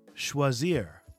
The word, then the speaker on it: choisir: UFC Quai Choisir.